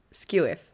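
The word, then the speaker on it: skew-whiff: How do you say squiff? – Skew whiff. – Skew whiff. – Yeah. – What do I say? School whiff. Oh, God. I've always said school whiff.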